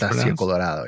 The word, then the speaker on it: Fantasia Colorado: Many of them have been lost to time, of course, but the local Hispanic and Chicano population dubbed the beast Fantasia Colorado, I believe is how it's pronounced. – Fantasia Colorado, yeah.